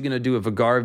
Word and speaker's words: Vhagar: However, I yield, I have heard the outcry, and in fact, someone has informed me that George does indeed say Vhagar. I usually try to go with the pronunciations that George gives, so that's how we'll say it, Vhagar.